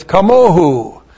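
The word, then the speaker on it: kamohu (כמוהו): K-A-M-O-H, long U. So when you have the preposition kaf, prefix to the paragogic syllable mo with the third person plural suffix, it's kamohu.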